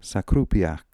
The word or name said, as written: Sakroopiak